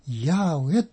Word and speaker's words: ヤーウェ: で、聖書では、エルの他に学者たちがヤーウェと発音します神様の固有のお名前があります。